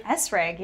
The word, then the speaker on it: Essrig: Esrig, yeah. – E-S-S-R-I-G.